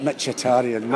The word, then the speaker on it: Mkhitaryan: Mkhitaryan. – How do you pronounce it? – I've got that Mkhitaryan.